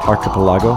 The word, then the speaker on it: Archipelago: Mike Snow with Archipelago. That's how you say it, right? – Archipelago.